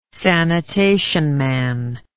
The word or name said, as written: sanitation man